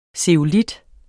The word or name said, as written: zeolit